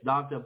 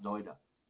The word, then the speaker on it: Dagda: I call it Doida. I say it's Dagda, but in Irish it's pronounced Doida.